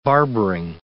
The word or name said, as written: barbering